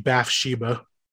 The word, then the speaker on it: Bathsheba: I looked it up. It's actually Bathsheba. – Okay, well that's not what my it's spelled Bath and then Sheba.